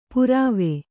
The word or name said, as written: ಪುರಾವೆ